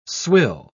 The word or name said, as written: swill